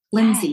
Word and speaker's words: Lindsey: And my name is spelled with an E, Lindsey.